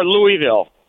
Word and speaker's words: Louisville: Is it Louisville or Louisville? – Louisville. – Louisville. – Louisville. – Yeah. – They you got to roll through the middle. – If you say Louisville to people from Louisville, they will look at you strange and call you a moron.